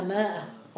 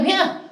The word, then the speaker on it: مئة: في القرآن الكثير يقرؤونها ماء وهذا خطأ هي مئة ولكن زادوا الألف لأنه لم يكن هناك تنقيط للغة